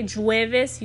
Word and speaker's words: jueves: For example, the word jueves, which means Thursday, it's spelled J U E V E S but you don't say jueves, you say jueves.